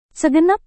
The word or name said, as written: segenap